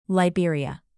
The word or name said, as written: liberia